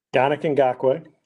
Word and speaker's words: Yannick Ngakoue: Yannick Ngakoue.